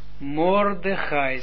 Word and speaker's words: Mordechai: De eerste klinker wordt als o uitgesproken. Dus Mordechai, zie je dat?